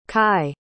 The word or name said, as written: cai